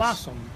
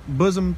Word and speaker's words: Blossom: Blossom. B-L-O-S-S-O-M. Blossom. – Cherry Bosom Festival.